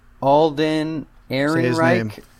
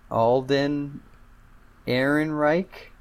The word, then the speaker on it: Alden Ehrenreich: No, nope, that was terrible. – Alden. – We're gonna sit here till you get this right. – Alden. And the man deserves his name pronounced correctly. – Alden Ehrenreich. – I think that works.